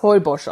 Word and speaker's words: Holbox: Geschrieben wird es wie die hohle Box. Also Holbox. H-O-L-B-O-X. Und wir glauben, dass das Heubosch ausgesprochen wird. – Bin mir ziemlich sicher. – Weil das wird, ich sag jetzt mal, ohne mich da besonders auszukennen, das wird Maya sein.